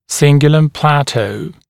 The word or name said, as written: cingulum plateau